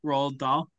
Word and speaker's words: Roald Dahl: Yeah, Roald Dahl.